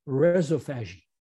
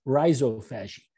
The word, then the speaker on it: rhizophagy: And without being disparaging, she pronounces it, and since we have somebody from Australia, she was Australian, she pronounces it rhizophagy. And for years, I struggled with it until I realized that was her Australian way of saying root, rhizo, and phagy. We say it differently in the United States, rhizophagy.